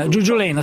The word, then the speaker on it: Giuggiolena: Giuggiolona, scusate. – Giuggiolena, sì, ho detto bene la prima.